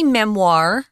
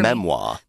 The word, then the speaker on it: memoir: I wanted to mention, we say memoir with that ugly R that you don't get with British English. Say it again for memoir. – Me. Memoir. Memoir. It's more like the French pronunciation, actually, except the French will put the memoir. Memoir is actually French. That's where we get this word from.